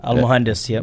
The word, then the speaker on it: al-Muhandis: So everybody knows about Soleimani and his little fucking friend that got murked on the Al-Muhandis. – Wait, how do you say it? Juandis? – Abu Mahdi Al-Muhandis. – Mahandis. – That's okay. Yeah, Al-Muhandis.